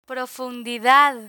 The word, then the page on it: profundidad: Spanish